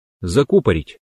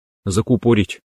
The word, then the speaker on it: закупорить: Например, правильно говорить закупорить, а мы часто говорим закупорить.